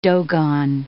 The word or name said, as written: Dogon